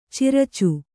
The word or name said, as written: ಚಿರಚ